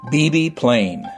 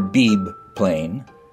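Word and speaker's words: Beebe Plain: BB Plain. Never Beeb Plain. BB Plain.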